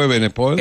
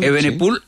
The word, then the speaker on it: Evenepoel: ¿Se dice así? ¿Sí? – Sí, Renko Evenepoel. – Evenepoel, sí.